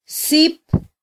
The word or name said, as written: sip